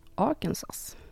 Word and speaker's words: Arkansas: Vår historia utspelar sig i den amerikanska delstaten Arkansas. – Arkansas? – Nej! – Jag tänkte inte på det när jag såg uttalsanvisningen, men det är Arkansas.